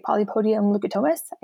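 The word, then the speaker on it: Polypodium leucotomos: if I'm going to say it right, polypodium lucatomus, I think, is that right? – Yeah.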